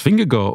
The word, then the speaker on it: Vingegaard: namelijk dat Jonas Vingegaard ook in de Vuelta zal starten ja.